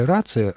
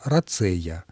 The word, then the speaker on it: рацея: Только ударение. Не рация, а рацея.